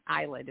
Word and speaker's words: islet: Over the years, and this is still ongoing research, but there's more than several programs doing islet. It's I-S-L-E-T, islet cells. The S is silent.